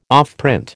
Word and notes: offprint: American English

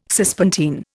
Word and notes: Cispontine: Indian

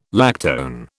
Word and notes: lactone: British English

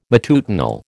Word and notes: matutinal: American English

